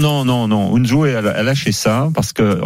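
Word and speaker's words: Unzué: Comment on prononce son nom Unzoué. Unzoué. Eusebio Unzoué qui a annoncé qu'il fallait.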